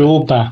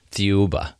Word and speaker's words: Thiaoouba: How do you pronounce Thiaoouba. – It? Thiaoouba.